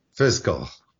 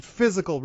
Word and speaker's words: fiscal: but you'll hear the word fiscal mispronounced. – Actually, it's more often the word physical that gets mispronounced, I think. – You think so? Okay, yeah. – Yeah, fiscal. – Yeah, and rather than physical responsibility here, I'm going to get my fiscal or something like that.